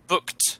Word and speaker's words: booked: So like I say book, but with a T at the end. Booked.